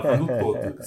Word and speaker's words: Canutto: É Canuto. C-A-N-U-T-T-O. Dois T's.